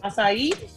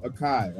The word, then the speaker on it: acai: Acai.